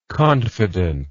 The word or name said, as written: confidentness